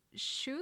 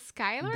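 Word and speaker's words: Schuyler: I'm not sure how to pronounce this one, because if I read it the way it's written to me, it's Schuler, but they pronounce it Skyler.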